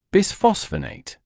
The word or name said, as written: bisphosphonate